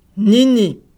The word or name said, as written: nini